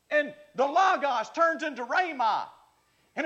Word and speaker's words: rhema: Not only is there logos, but there's also the Greek word that's, that's, that's pronounced rhema.